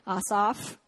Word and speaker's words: Asaph: Everybody say Asaph. I had to look up how to say this name because I was tempted to say it in many other ways. Asaph.